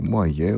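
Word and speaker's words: Moyet: Μία τραγουδίστρια που έχουμε ακούσει αρκετές φορές είναι η Άλισον Μόγε, όπως λένε οι Άγγλοι, Μόγε, όπως την προφέρουν οι Γάλλοι.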